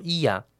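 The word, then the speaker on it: Oia: 首先大致介绍一下圣托里尼的主要两个区域，一是叫伊亚，念作伊亚，O 不发音；一个叫 Villa，前者幽静，后者热闹。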